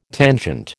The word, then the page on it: tangent: American English